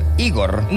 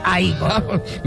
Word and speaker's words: Igor: Tú debes ser Igor. – No, se pronuncia a Igor.